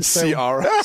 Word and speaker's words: Ciara: That's how you say it. Say it again. – Ciara. – Ciara, is that her name? – Yeah, Ciara. I like the way you said it better.